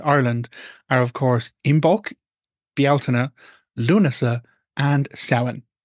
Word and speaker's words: Bealtaine: Now, those are the pronunciations of those words. It's not ever and never has been Samhain and never will be. It is Samhain. Also, it's never Beltane. It's Bealtaine. So those are the words.